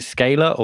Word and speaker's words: scalar: but is it scalar or scala? – Scalar. – Scalar.